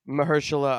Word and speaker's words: Mahershala: That Marshala Ali, am, am I pronouncing his name right? Mahershala Ali, I believe.